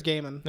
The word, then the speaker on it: Gaiman: I don't know if it's pronounced Gaiman or got. – Pretty sure it's Gaiman.